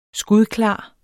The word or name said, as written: skudklar